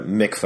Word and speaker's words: mikveh: A mikveh, or a baptismal font.